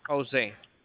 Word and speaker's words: Jose: maybe Jose.